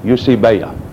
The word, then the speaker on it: eusebeia: The word that is used for godliness, the inspired God-breathed word, is eusebeia. The E-I is like a long A, eusebeia.